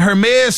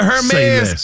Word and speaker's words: Hermes: Savannah James was on a podcast the other day, and she was speaking about her experience at a Hermes store in Italy. Is that how you see? I always hit the H. – H is silent.